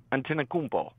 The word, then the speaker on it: Antetokounmpo: Yeah, there's some controversy on how it's pronounced, but I believe it's Antetokounmpo.